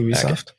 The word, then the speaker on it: Ubisoft: And because recently another game company, Ubisoft, pronounced Ubisoft. – Yeah, I think most people pronounce it Ubisoft.